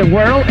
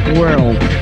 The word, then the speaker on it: World: Esto de World está muy de moda últimamente, además es que me gusta pronunciarlo, World. Es así como si fueras a mandar la lengua a la puerta de al lado, World.